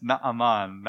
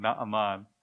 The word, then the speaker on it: Naaman: Yeah, you're getting pretty good, but you need to still correct your pronunciation. It's Naaman, Naaman. As you hear that word, Naaman, that name, it sounds very like Naomi, right?